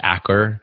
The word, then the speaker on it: Achor: Yeah, well, one of my favorite authors, Sean, and I think his last name is pronounced Acher, A-C-H-O-R, he wrote a book called The Happiness Advantage.